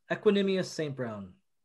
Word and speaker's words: Equanimeous St. Brown: Is it Equanimeous St. Brown.